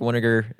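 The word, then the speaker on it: Winegar: I am at Patrick Winiger. That's vinegar with a W.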